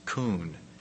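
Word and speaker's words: kun (כון): The word that is used, the Hebrew word, is K-U-W-N, and is pronounced kun, K-U-W-N.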